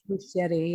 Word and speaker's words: Muschietti: Muschietti? – Muschietti, yeah, he's Argentine.